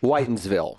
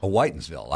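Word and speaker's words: Whitinsville: This is from Placido in Whittonsville, Massachusetts. – Whitensville. – Whitensville. I apologize. – That's how they pronounce it.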